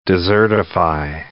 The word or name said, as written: desertify